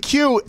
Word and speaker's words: Kui: Jonathan Q, and your name is really Q. – It is. It is, in fact, pronounced Q, yes. – That's awesome. It's spelled K-U-I, but you are a member of the Q continuum in my eyes.